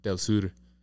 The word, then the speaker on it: Del Sur: Does he go like ser or sur? – I think sur. – Because the U sound in Spanish is U, so Del Sur.